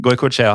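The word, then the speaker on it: Goikoetxea: Jeg tror det uttales Goikoetska. – Ja, til dere lyttere, det skriver, altså etternavnet han skrives G-O-I-K-O-E og så T-X-E-A.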